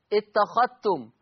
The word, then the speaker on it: اتخذتم: طيب الفائدة الأخرى، أضغم ورش، أضغم ورش الذال الساكنة في التاء المتحركة، أضغم ورش الذال الساكنة في التاء المتحركة في لفظ اتخذتم لا يتلفظ بالذال، اتخذتم،